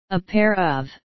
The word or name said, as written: a pair of